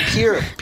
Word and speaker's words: Pierre: That was in Pier, South Dakota. – Pier, South Dakota. Okay. – Yeah. – Which is spelled Pierre, by the way.